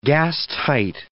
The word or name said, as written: gastight